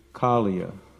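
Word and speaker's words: Kāliya: It's Kaliya, ka, ka, ka. Think of a crow, ka, ka, ka. Kala means black. Kaliya, not Kaliya. There's no long I, Kaliya.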